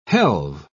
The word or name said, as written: helve